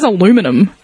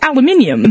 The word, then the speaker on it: aluminium: Like, for instance, like, what the fuck is aluminum? Like, it's aluminium.